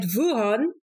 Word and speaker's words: Wuhan: And then after your PhD, you did your postdoc at the School of Philosophy at Wuhan. Am I pronouncing that correctly? – Perfectly, I would say.